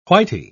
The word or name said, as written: whitey